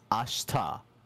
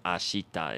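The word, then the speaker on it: ashta (明日): Yeah, did you all notice that, like, he kind of didn't really pronounce the she there? It's like, instead of ashita, it's ashta. Like, there's this thing in Japanese called high-vowel devoicing, and that's the whole reason why we don't really say the E sound there.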